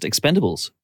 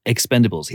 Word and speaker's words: Expend4bles: And he pronounces it just Expendables. So that seems to be the official title of the movie, is just Expendables. He didn't go Expend4bles.